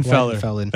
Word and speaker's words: Weidenfeller: Weidenfeller.